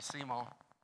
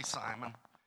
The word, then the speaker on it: Simeon: You know, down in southwest Louisiana, we might say Simo. The name was Simo. Some might say Simon. But it's S-I-M-E-O-N. It's Simeon is the way it would properly be pronounced.